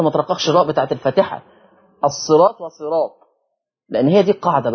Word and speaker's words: الصراط: خلي بالكم، شوف أنا أقولها إزاي، الصراط. طب ليه ما أقولش الصراط؟ الصراط حتى النطق بتاعها مش جاي، الصاد مكسورة الصي والراء، يبقى أقول الصراء مفروض كده. قال لك بس وراها ألف وطاء، الصراط طاء، عشان كده منع ترقيق الراء.